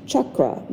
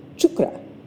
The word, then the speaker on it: chakra: Sometimes you may hear it pronounced chakra, with a hard CH at the front, chakra. And that's okay, but the Sanskrit pronunciation of the word is actually chukra, chukra.